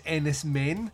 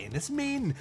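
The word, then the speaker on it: Enys Men: And now he's back and he's doing Ennis Main, which a lot of people are pronouncing, including myself, as Ennis Men, until we all checked out Mark Kermode's review of it and then found that it's actually Ennis Main, because it involves a stone-like structure or island which is known as a main, which is spelt Men.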